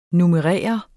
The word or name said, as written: NUMMEREREDE